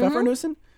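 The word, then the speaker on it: Pfeffernüsse: Did I pronounce that right, Maiken? Mm-hmm. Pfeffernussen? Mm-hmm. That's like a German little dot cookie? – Yeah, it's a round cookie, and pfeffer is the word for pepper.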